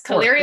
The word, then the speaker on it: Caliri: Yes, Caliri is correct.